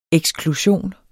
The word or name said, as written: eksklusion